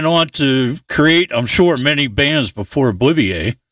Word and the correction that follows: Oblivia: Right, and the proper pronunciation is Oblivia for the band.